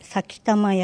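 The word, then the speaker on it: 幸魂: 先玉宿るは、幸せの魂と書いて先玉宿る。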